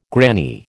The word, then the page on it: granny: American English